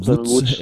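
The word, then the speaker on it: Łódź: Og Mathias Rygaard, han har lært mig, at selvom man staver det Lodz, så udtales det jo Lodz.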